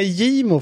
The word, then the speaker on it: Gimo: Ja, uttals med Gimo faktiskt. Gimo, ja, kanske.